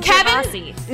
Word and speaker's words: Gervasi: Both of you said my last name wrong you both said my last name different ways at the same time, and I loved it. – Wait. Oh, no. I'm sorry. – No, Kevin. Gervasi. No, Kevin actually said it right.